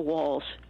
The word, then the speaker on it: Walz: You know, there's how do you spell Walls? – W-A-L-Z. – W-A-L-L-S. – No, no. – Thank you. – It's pronounced as if it was L-L-S, but it's L-Z.